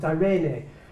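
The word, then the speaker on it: irene (εἰρήνη): And in the New Testament, that word is irene. It's pronounced irene.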